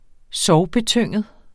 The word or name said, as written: sorgbetynget